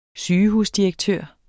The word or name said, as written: sygehusdirektør